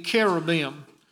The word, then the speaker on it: cherubim: The word cherubim, as we pronounce it in English, CH is most always in the Bible pronounced as a K. And coming from the Greek, it would be cherubim.